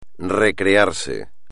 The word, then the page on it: recrearse: espagnol